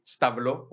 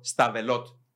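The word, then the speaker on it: Stavelot: Ε, εάν πάρει στο ΣΠΑ, είναι εξαιρετικό να επισκεφτεί εκείνο το μικρό χορκουδάκι σταυλό, όπω το προφέρουν οι τόπικοι, σταυλό, σταβελότ το λέμε εμεί, ναι.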